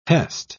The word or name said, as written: hest